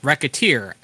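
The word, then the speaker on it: Recettear: However, the title is pronounced Racketeer, as in racketeer, considering you are running a shop and doing what you can to make as much money as you can.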